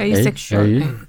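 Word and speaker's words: asexual: 이걸 어떻게 읽습니까? 에라고 읽어야 되나? 어라고 읽어야 되나? 에섹슈얼이라고 읽나? – 그냥 에이섹슈얼 아닌가? – 에이섹슈얼.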